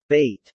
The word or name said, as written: bait